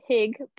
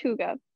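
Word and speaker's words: hygge: Maybe you've heard it been said hig, but it's truly pronounced huga. I don't know much Danish, but I know that.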